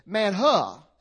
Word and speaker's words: manna: In fact, we pronounce it M-A-N-N-A, but in the Hebrew, it's more M-A-N-H-U-H. Manha is really how it's better pronounced.